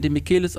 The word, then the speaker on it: Demichelis: Ist mir egal, wie es richtig ausgesprochen wird übrigens, Demichelis. Man spricht den Demichelis aus, Punkt aus.